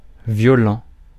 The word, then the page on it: violent: vjɔ.lɑ̃; France